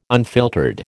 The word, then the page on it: unfiltered: American English